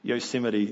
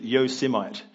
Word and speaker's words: Yosemite: I should just say, Yosemite, there was this, there's this politician who pronounced that word wrong. He said Yosemite, which sounds ridiculous.